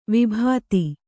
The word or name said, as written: ವಿಭೂತಿ